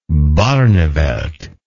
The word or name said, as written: Barneveldt